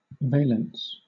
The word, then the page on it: valence: British English